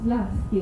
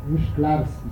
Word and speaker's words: Szklarski: appunto io lo chiamo Slaski, ma tu mi hai corretto 7 volte, quindi dillo giusto, oppure dillo tu. – Insomma, la pronuncia è difficile perché in polacco ci stanno queste lettere tipo sci, che qui non esistono, Szklarski si chiama esattamente,